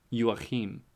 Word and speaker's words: Joachim: Spelt like Joe Chim. – Okay. – Pronounced Joachim.